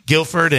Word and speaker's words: Guilford: Haley signed to go to Guilford, I think is how you pronounce it. Yeah, Guilford.